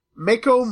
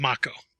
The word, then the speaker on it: Mako: So the follow-up thing is the movie Pacific Rim inspires an alternate test called the Mako Mori test. – Actually, it's Mako. – Mako Mori. Yeah, Mako that actually Mori. Flows a lot better. – That's the correct pronunciation.